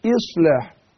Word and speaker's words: إصلاح: الهمزة المكسورة زي ما قلت إن هي بتتنطق، مسألة تقريبية إن هي بتتنطق زي الإي بالإنجليزي، مش إي، ما قولش إصلاح، لا، أقول إصلاح هكذا.